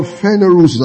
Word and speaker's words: phanerosis: Now, the word manifestation here is a Greek word phanerosis. I'll spell it for you. P-H-A-N-E. R-O-S-I-S. The verb of it is phaneroo. P-H-A-N-E-R-O-O.